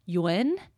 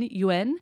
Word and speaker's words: Yuan: And turns out that it's actually pronounced in Mandarin something closer to Yuan, or like the letter U-N, Yuan.